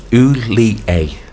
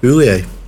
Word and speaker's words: Houllier: Yes, pronounced Houllier. – Houllier. All right. Houllier. – Yeah. – Just like Gerrard.